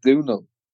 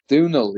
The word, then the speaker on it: Domhnaill: Close. I'll give you a B plus. It's actually Domhnaill. – Domhnaill, okay. – Domhnaill, yeah. Phonetically impossible Irish name.